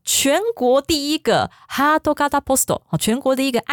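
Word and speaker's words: 哈 多 嘎 达 (ハート形): 那卡塔原本是念卡塔基，好，但是因为它接在哈多这个字后面，好，就念成嘎达。哈多嘎达就是爱心形状的。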